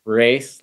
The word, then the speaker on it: Race: Race, like you run a race.